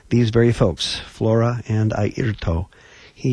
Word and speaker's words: Airto: Points to his eye, his ear, and his toe, Airto Moreira.